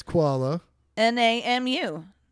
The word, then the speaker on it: Kualanamu: What are the letters? – K-U-A-L-A. – That's Kuala. – N-A-M-U. Namu. – Kualanamu. – Okay, well, fucking Mr. Harvard over here. Five Kualanamu International Airport employees were fired.